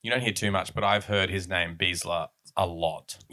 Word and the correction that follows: Bielsa: Yeah, he's Bielsa. Really good. – Bielsa, is that how it's said?